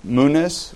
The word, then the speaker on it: munus: Uh, then he went on uh, to discuss basically the, the uh, basic introduction of chariot racing, theater, and uh, the munus. Would that be how it's pronounced? The munis? Munus.